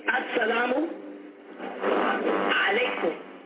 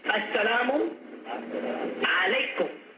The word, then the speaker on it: Assalamu Alaikum: Say, Assalamu Alaikum. Assalamu Alaikum.